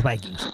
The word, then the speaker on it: Vikings: Vikings. Lo digo bien, ¿verdad? – Correcto. – A los Vikings.